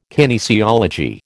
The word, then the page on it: kinesiology: American English